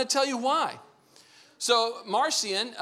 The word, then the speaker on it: Marcion: I'm going to show you a man by the name of Marcion, who you can also pronounce as Martian.